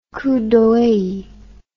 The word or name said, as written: coup d'oeil